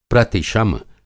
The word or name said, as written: प्रतिशम